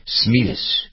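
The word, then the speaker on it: Smedes: In November 1902, Roosevelt, who was a hunter, went on a hunting excursion organized by the governor of Mississippi, Andrew Longino, in Smedis, Mississippi. Roosevelt was accompanied by some aides. Other who's what? Smedis. – Where was it? Oh. – Smedis. It's S-M-E-D-E-S. How would you pronounce it? Smedis. M-I-S-S-I-S-S-I-P-P-I.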